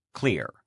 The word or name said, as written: clear